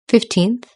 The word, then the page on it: fifteenth: British English